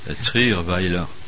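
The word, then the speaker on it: Trierweiler: Ainsi, nous apprenons que Mme Trey Veller, tu sais très bien dire son nom, toi. – Trier Weiler. – Oh là là là là. – Ça se prononce comme ça, jeune homme.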